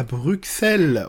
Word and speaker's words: Bruxelles: Pas du X, en fait c'est deux S euh, quand on l'écrit en néerlandais ou en flamand. Euh, et, voilà, il le prononce comme ça, Bruxelles.